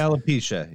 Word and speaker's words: alopecia: Alopecia.